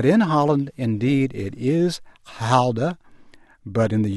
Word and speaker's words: Gouda: the proper way to pronounce Gouda. It is not Gouda. It is Gouda. Add as much guttural as you like to the front."